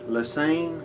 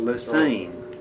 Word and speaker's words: Lesesne: And then a guy named Henry Lesesne, L-E-S-E-S-N-E. – Lesane. Yeah, that's the way they pronounce it. – Lesane.